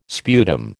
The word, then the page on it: sputum: American English